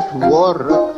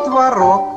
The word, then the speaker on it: творог: А творог? – Можно творог. – А можно и творог.